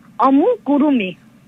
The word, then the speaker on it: amigurumi: Amigurumi.